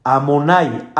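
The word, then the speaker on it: Adonai: este nombre no se pronuncia como está escrito. Yo no pronuncio la Yud, ni la Hei, ni la Vav. Yo pronuncio el nombre que está aquí adentro. Amonai, Alef Dalet, Nun, Yud. Este es el nombre que yo pronuncio.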